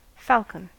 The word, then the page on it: falcon: American English